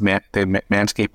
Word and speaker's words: Manscaped: I can't say it with a German accent, but it's they pronounce it Manscaped also.